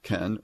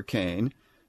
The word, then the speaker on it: ken: And in the Hebrew, that expression starts with the Hebrew word ken, pronounced ken or kane, in the next line.